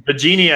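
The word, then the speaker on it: Biagini: CJ told me a way to say it last time, and then like everybody told me it was Bigini. So whatever. Bigini, I think, is actually how it's pronounced, so I'm just going to roll with that.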